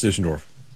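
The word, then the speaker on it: Deutschendorf: It's D-E-U-T-S-C-H... E-N-D-O-R-F Jr. You think that's pronounced Dusseldorf? – It is pronounced Dusseldorf. I was in Germany, 2 months ago. That's exactly how they would have said it.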